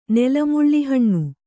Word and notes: ನೆಲಮುಳ್ಳಿಹಣ್ಣು: Kannada

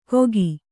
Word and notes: ಗೋಕಿ: kannada

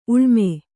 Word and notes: ಉಳ್ಮೆ: uḷme

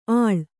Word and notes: ಆಳ್: āḷ

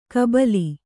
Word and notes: ಕಬ್ಬಿಲೆ: kabbile

